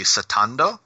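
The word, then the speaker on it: satando (スタンド): It's more related to how it's pronounced in the original Japanese would be satando, which is very similar to the expression ghostly ripple, and that would be related to therefore related to Hamon or the ripple.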